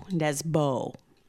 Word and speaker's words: Nesbø: It's spelled N-E-S-B-O, Nesbø, for those of us who are obviously Americans.